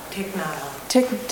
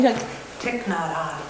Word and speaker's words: Thich Nhat Hanh: Thich Nhat Hanh. – Tell me. – Thich Nhat Hanh.